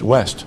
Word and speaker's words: ouest: But the French word for West is spelled O-U-E-S-T, and it's pronounced West. – Hmm. – So they run those vowels together and get a W sound.